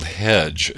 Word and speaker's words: Headge: The group is called Hedge. It's spelled H-E-A-D-G-E.